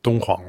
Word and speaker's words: Dunhuang: On est là, vous allez m'aider d'abord à prononcer, ensuite à localiser, à Dunhuang ? – Dunhuang, oui.